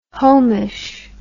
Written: homish

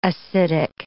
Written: acidic

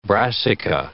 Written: brassica

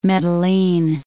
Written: Medellin

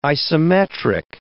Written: isometric

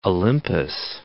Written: Olympus, Mount

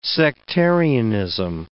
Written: sectarianism

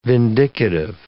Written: vindicative

